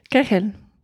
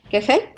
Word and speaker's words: Kegel: Es que no, sé si se pronuncia Kegel o Kegel. – Kegel. – Kegel.